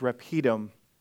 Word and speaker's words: Rephidim: And what I think it's Rephidim is the way that I heard it's pronounced.